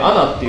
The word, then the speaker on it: ANA: ANA、アナって読むじゃん。